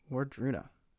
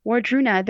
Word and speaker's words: Wardruna: Wadruna. – Oh, yeah. – Can you spell that? – And what they do is Wardruna. – Wardruna. – Wardruna.